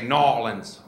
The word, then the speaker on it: New Orleans: You got to say Nawlins. Yeah, they're very picky about that. Yeah, Nawlins. You guys did it. Yeah, they're weird about it. Nawlins, like you're choking on a peanut.